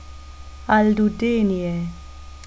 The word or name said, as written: aldudeenie